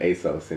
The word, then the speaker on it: ASOS: Well, we pronounce it ASOS in the United States.